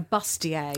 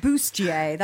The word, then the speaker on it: bustier: Well, she went out, got, good God, a woman of 50 wearing a bustier. – How is she even still alive? – and there was this whole kind of bustier. That's how you're supposed to pronounce it.